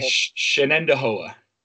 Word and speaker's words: Shenandoah: Yeah, Shenandoah. – Shenandoah. That's not hard. – People always get mixed up with Shenandoah. There's an extra en in there, and that's right, and it's spelled a little different.